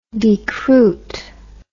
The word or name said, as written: decruitment